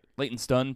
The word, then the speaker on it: Leytonstone: Leytonstone, probably. It's spelled stone, but they say stun kind of thing.